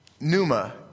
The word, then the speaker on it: pneuma: And so this word contextually is, let me say it right, pneuma is the benefits and life of God pressing its influence into the whole of who you are.